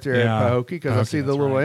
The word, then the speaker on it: Pahokee: How do you pronounce it? Pahokee? It's Pahokee.